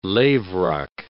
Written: lavrock

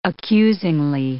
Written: accusingly

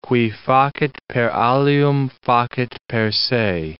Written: qui facit per alium facit per se

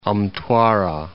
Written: Mtwara

